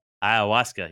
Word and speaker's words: ayahuasca: Ayahuasca. – Ayahuasca, yeah.